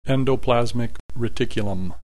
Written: endoplasmic reticulum

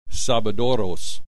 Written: sabadoros

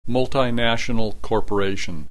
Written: multinational corporation

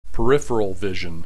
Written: peripheral vision

